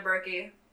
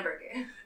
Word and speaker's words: Berkey: It'll be Brianna Berkey, like Albuquerque, because no one can spell my last name anyways. So, Brianna Berkey.